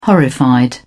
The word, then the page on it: horrified: British English